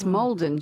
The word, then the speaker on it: Maldon: My uncle, who lived in Maldon most of his life, pronounced it Maldon. Maldon. – That's the emphasis. Yeah, emphasis. – I don't know. It's Maldon, surely.